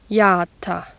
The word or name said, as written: jata